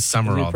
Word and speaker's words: Sumrall: The Florida football coach, John Sumrall. Is that how you say it? He's new. S-U-M-R-A-L-L. – I know, it's weird. It is Sumrall, though. – Is it pronounced Sumrall? – Yeah. – I feel like there's a letter missing. – It is. It's not spelled right, but that's how it's pronounced.